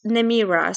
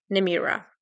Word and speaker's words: Nemira: Yes, it's Nemira. So it's a bit Nemira. More, the E is okay. A bit more Nemira. Yeah.